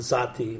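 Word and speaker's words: sahti: is to make the good sati. – And I've read a little bit about the sati. That's how you say it, sati? – Sati is the right name.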